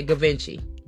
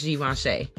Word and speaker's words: Givenchy: A lot of people pronounce that Givenchy, but it's Givenchy.